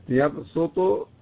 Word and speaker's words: يبسط: المقدم في الأداء في كلمة يبسط بالسين، أما المسيطرون المقدم في الأداء فيها هو الصاد.